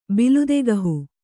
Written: ಬಲುದಗಹು

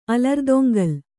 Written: ಅಲರ್ದೊಂಗಲ್

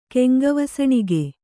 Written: ಕೆಂಗವಸಣಿಗೆ

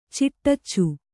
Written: ಚಿಟ್ಟಚ್ಚು

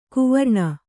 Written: ಕುವರ್ಣ

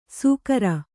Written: ಸುಕರ

